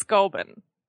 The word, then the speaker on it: Goulburn: It's Goulburn. – Goulburn? – Goulburn. – Goulburn. But we don't pronounce things like that. Like, y'all say Canberra, but it's Canberra. – It's what? – It's spelled Goulburn.